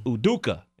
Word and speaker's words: Udoka: Udoka. Did I say that right? – Yeah.